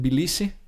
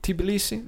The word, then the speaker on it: Tbilisi: From Turkey, you would come into Armenia, potentially Georgia, stop in Tbilisi. – Yeah. – Do you pronounce the T? Tbilisi? No, Tbilisi. – I think if they didn't want the T, they shouldn't have put it there.